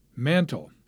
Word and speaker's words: Mantell: His name was originally Mantell, one L at the end, but he changed it, adding the L, putting the accent on the second syllable.